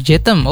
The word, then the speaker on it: je t'aime: Jadi kalau dalam bahasa Prancis romansa itu adalah je t'aime atau dibaca je t'aime. Jetem, oh Jetem bacanya teman-teman.